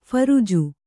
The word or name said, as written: ಫರ್ಜು